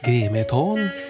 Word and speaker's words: Grimeton: Radiostationen i Grimeton uttalas Grimeton är ett industriminne som sedan 2004 står på Unescos lista över världsarv.